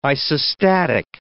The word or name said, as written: isostatic